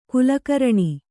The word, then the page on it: ಖಲಕರಣ: khalakaraṇa